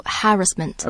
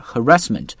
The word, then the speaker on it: harassment: Sexual harassment。 – OK， you can pronounce either harassment or harassment。 – Harassment，这两个都对。